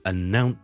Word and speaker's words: announce: 今日同大家讲一个字嘅发音，因为呢小叔发觉呢好多人都读得麻麻地，但係呢个呢系我哋成日都见到嘅字嚟嘅，就系、是、呢我哋宣布一啲事 ,to announce something, announce。广东人呢我哋通常都会读成 announce 或者 announce 咁样，其实系应该读 announce, announce, announce。